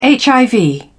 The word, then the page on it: hiv: British English